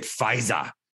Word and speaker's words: Pfizer: By the way, that's why I have to say it, Pfizer. It's the proud German pronunciation of the pharmaceutical company, Pfizer.